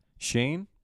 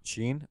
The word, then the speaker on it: Sheen: Shane was a really good character in Jimmy Neutron. – If only you got his name right. – Isn't it Sean? – Sheen.